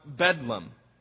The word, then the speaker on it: Bedlam: St. Mary of Bethlehem was shortened to one word, Bethlehem, but pronounced Bedlam.